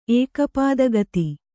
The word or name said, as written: ಏಕಪಾದಗತಿ